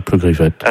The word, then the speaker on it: Pleugriffette: Comme ça se prononce, hein, Pleugriffette. – Vous pouvez m'appeler, s'il vous plaît? – Oui, Pleugriffette, en fait.